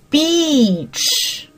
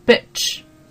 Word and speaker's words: beach: 碧池哈，OK，那个那个词是叫念什么？B I T C H，很容易跟这个音搞错，很容易把这个 beach 念成了 bitch。那一个是长元音 e，你看我们这个 b e a e a 组合的时候，这两个字母组合的时候，一般会念成什么？长元音的那个 e，OK，那比如说接触到这个词叫做什么？reach 也是一样的，OK，那么啊，不要把这个长元音和短元音搞错了。